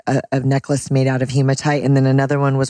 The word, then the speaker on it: hematite: if I'm pronouncing it right, hematite? – Hematite. Love hematite.